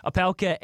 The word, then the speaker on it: Opelka: Opelka and Harris,